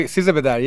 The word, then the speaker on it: Sizdah Bedar: What is Sizdah Bedar or? – Oh yeah, you did. Yeah, Sizdah Bedar.